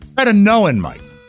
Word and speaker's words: tretinoin: Tretinoin. Tretasplingsentin? – Tretinoin, Mike.